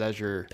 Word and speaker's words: Azure: Being Greg Miller drives me to no end when he mispronounces Azure. – Because it's fucking Azure. – It's Azure. – It's a shade of blue.